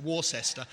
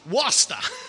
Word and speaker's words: Worcester: it's American, probably pronounce it Worcester. Worcester.